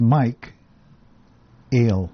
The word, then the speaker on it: MIKEALE: How do you pronounce that? That's a little tricky. Yeah, the spelling when you think about it. – Actually, when you look at it, it's Mike Ale.